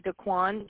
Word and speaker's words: Daquan: It's Daquan Jenkins.